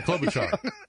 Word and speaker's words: Klobuchar: All right, I'll say, again, I can't pronounce the last name, Kaloralora? – Klobuchar? – Yeah, Klobuchar.